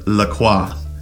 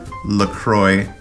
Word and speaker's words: La Croix: She kept pronouncing it La Croix. And I kept correcting her, of course, after listening to you pronounce it with the French accent for years. I kept telling her that say it is La Croix, and they are obsessed with it, so they must be right. Well, she looked it up and surprised me with the knowledge that it rhymes with enjoy.